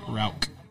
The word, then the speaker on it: Rauch: There definitely was that, but when referring to the smoke beer, it's a really hard, like, K sound, like Rauch. – Rauch, okay.